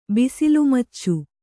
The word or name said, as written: ಬಿಸಿಲುಮಚ್ಚು